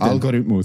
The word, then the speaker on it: Algorithmus: Da hat der Algorithmus, ne, wie sagt man es? – Algorithmus. – Der Algorithmus. Das ist ja viel geiler. Der Algorithmus. Ja, Betonung ist immer auf dem ersten Vokal.